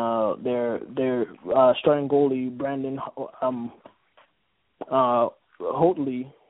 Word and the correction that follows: Holtby: Holtby Holtby whatever you want to pronounce his name